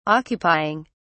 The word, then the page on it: occupying: British English